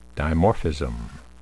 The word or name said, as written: dimorphism